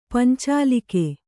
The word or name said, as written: ಪಾಂಚಾಲಿಕೆ